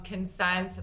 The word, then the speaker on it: Kensent: So it's Consent, but it's spelled K-E-N-S-E-N-T.